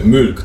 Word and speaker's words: Mulk: Ale, ale to nie jest Milk, raczej się nie wymawia Milk. Milk. – Czyli Mulk, tak? Takim, Mulk. Z takim umlautem trochę.